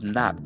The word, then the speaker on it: knock: And then the third thing was knock. The K is silent.